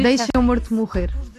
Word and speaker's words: Deixa o Morto Morrer: No, you Deixa o Morto Morrer. We eat the vowels, so it's very hard.